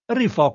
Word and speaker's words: Rifò: Rifò, come rifare con l'accento Rifò.